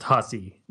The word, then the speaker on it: HSY: And I was like, oh, it's pronounced Hussy. That's the actual name of the band.